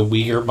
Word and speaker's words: Uyghur: The Uyghur Muslims are banned, and that's how you say it.